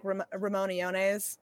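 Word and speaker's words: Allones: Yeah, well, I think double L in Spanish is pronounced like a Y, so I think it's probably technically like Ramoniones.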